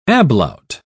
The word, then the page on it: ablaut: British English